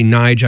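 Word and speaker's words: Nydge: Next up is new music from a track from a guy named Nidge, and that's N-Y-D-G-E. Actually, Nige, I guess, is how you pronounce it, because his given name is Nigel.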